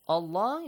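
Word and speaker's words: الله: Allah is pronounced heavily. The letter lam.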